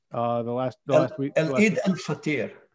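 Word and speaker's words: Eid al-Fitr: Is it pronounced Eid? – Eid al-Fatir.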